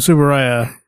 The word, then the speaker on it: Tsuburaya: Because it's not from Tsuburaya.